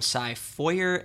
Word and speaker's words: Feuer: It's spelled F-E-U-E-R, so I'm just going to call him Cy Feuer and hope that that's correct.